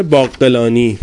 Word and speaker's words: باقلانی: کتاب اعجاز القرآن باقلانی، قافش رو باید یزدی تلفظ کرد، از رکائز اعجاز القرآن هست.